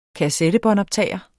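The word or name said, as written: kassettebåndoptager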